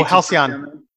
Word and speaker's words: Halcyon: Uh, we went to the Blue Star Brewery, the Halicon. – Oh, Halcyon. – Yeah, House. Is that how you say it? – Yeah, yeah.